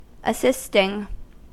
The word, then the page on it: assisting: American English